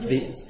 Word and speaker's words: viands: Viands? Is that how you say it? – Yeah, that's things. – Produce and foods and things. Pronounced viands.